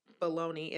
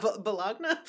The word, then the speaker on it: Bologna: It's not Bologna. – Bologna. – Bologna. Woo! – Bologna. – It spelled like bologna. That's why I was like, nope, that's wrong. – Bologna. – Thank you. – Yep. Bologna, Italy.